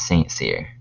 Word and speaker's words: St. Cyr: My last name is St. Cyr, written S-T-C-Y-R.